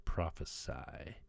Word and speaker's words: prophesy: The word prophecy spelled with C is a noun, and when you spell it with an S, it becomes a verb, which is pronounced prophesy.